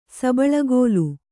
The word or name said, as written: ಸಬಳಗೋಲು